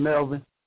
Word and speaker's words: Melvin: That's my name, Melvin.